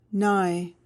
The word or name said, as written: nigh